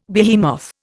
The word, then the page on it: behemoth: Indian